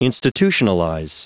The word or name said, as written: institutionalize